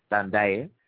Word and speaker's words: Ndadaye: He had replaced Melchior Ndadaye, and I always get these names a little mixed up because I'm not saying with the right accent, so bear with me. Melchior, last name is spelled N-D-A-N-D-A-Y-E, Ndadaye.